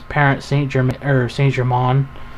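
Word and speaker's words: Paris Saint-Germain: Their actual name is Parent Saint-Germain, is how it's pronounced.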